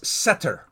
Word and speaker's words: setter: But in Psalm 91:1, the Hebrew word there, he who dwells in the shelter, the Hebrew word is setter. You might spell it S-E-T-T-E-R in terms of a transliteration of the Hebrew.